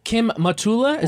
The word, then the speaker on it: Kim Matula: Kim Matula, is that how you pronounce it? – Mm-hmm.